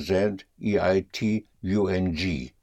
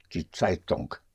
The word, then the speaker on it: Zeitung: Spelled Z or Z E I T U N G. Die Zeitung. And the NG in German, unlike in English, the NG is pronounced. Zeitung. The G comes out, die Zeitung.